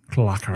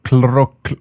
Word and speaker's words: CLROKR: Det är nämligen som så här att en hackare som kallar sig för CLROKR, Clarker. – Jag gillar uttalet, det låter rätt. – Clarker. Men du försöker uttala det själv då, ni ser jävla enkelt. Där har du det i chatten. – Clarker. Jag tror du sa precis rätt.